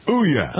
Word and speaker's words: Ouya: Ouya. – Oh, that sounds good to me. – Ouya. Is that like booyah? What's that like? Booyah, ooyah. All right, I'm being silly.